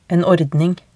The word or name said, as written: en ordning